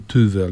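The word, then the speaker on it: Tove: I podobnym przypadkiem jest y, pierwsza książka naszej absolutnie ukochanej autorki, czyli Towy Jansson, wymawianej poprawnie Tywy, ale ja się nie nauczę.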